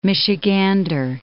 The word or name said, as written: Michigander